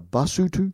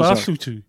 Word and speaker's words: Basotho: The people are the Basotho. One of them is Basotho. – Basotho, that's the plural.